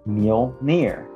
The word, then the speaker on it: Mjolnir: Say it like mule. – Oh, mule. – Uh-huh, near. – Near. – Yeah, mule, near. – Mule, near. – Uh-huh. Yeah, that's how you're supposed to say it.